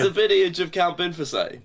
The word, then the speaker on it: Binface: Binface is actually pronounced Binfasay.